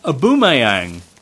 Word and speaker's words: Aubameyang: Aubameyang. – Yeah. Well, I think... – That's a great name. Yes. Did I say it right? – Yes, more or less.